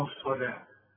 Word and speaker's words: الصلاة: ويقيمون الصلاه. الصلاه مطلوبه بالواو لكن تنطق بالالف.